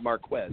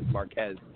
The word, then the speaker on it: Marquez: Well, actually, I'll go with Marquez Valdez-Scantling, and I finally am saying his name correctly. Not Marquez, Marquez Valdez-Scantling,